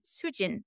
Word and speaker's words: Soojin: And I said my name in a Korean pronunciation because I didn't speak English. And I said, Soojin.